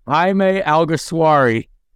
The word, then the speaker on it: Alguersuari: Jaime Alguersuari.